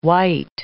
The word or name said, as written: wight